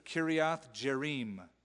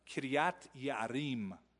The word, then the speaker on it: Kiriath Jearim: So they sent the messengers to the inhabitants of Kiriath Jearim. I mispronounced that on purpose because the Hebrew way is just a little more difficult sounding. Kiriath Yearim would be the right way.